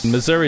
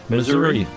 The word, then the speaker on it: Missouri: Missouri is the only US state that doesn't have one single correct pronunciation, so there's no actual right way to say it. – Missouri. – I've always called it Missouri. – Missouri. – However you want to say it, there's actually no correct pronunciation.